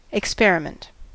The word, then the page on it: experiment: American English